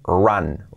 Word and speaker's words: run: Importante, run, la pronunciación que no es run, ra, ra, ra, la R, así, y el sonido es run, run, run, run, en pasado ran, ran, ea, ran, y en participio run, run, ran, run, así sería.